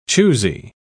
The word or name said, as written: choosy